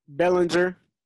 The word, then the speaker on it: Bellinger: How you pronounce his last name? – Bellinger. Eric Bellinger. Bellinger. I thought so.